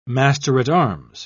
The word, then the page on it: master-at-arms: British English